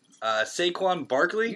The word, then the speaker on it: Saquon Barkley: Now, I'm probably going to butcher his name. Saquon Barkley. – You nailed it, man.